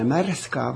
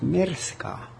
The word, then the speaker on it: Märska: Ta ei olnud mitte märska, vaid merska. Ja võtta ongi rootsi keelega ongi niimoodi, et e-täht R-i ees hääldatakse nagu ää.